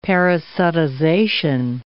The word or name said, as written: parasitization